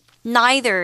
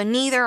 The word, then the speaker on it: neither: Neither is another way for you to pronounce it. Both are fine. So neither or neither, these two ways of pronouncing the word is okay.